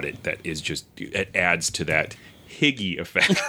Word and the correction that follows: hygge: Higge. It's pronounced. See if we can get this to play. – Hooga.